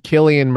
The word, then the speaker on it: Cillian: Killian Murphy. It's Killian. Killian. Pronounced Killian.